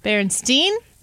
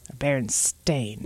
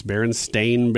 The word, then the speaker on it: Berenstain: Baron, S-T-E-I-N. – That's the way you pronounce it. – No, I remember the E. – You remember the E because there is no E in it. It's Berenstain Bears.